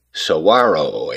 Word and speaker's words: saguaro: First, pronunciation, the go, is silent, sounding like a WH. It's said, saguaro, a word originating from indigenous peoples of the Sonoran Desert, meaning giant tree-like cactus.